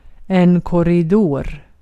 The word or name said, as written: korridor